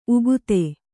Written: ಉಕ್ಥ

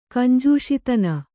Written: ಕಂಜೂಷಿತನ

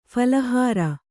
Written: ಫಲಾಹಾರ

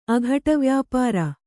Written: ಅಘಟವ್ಯಾಪಾರ

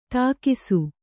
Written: ಥಕಾಸು

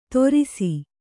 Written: ತೊರಿಸಿ

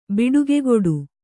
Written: ಬಿಡುಗೆಗುಡು

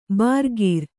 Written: ಭರಿಕರ

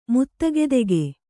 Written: ಮುತ್ತಗೆದೆಗೆ

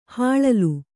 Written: ಹಿಲ್ಲೋಲ

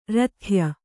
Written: ರಥ್ಯ

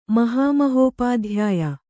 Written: ಮಹಾಮಹೋಪಾಧ್ಯಾಯ

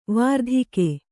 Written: ವರ್ತಿಕೆ

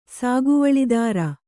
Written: ಸಾಗುವಳಿದಾರ

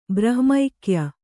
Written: ಬ್ರಹ್ಮೈಕ್ಯ

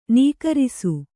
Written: ನಕಾರಿಸು

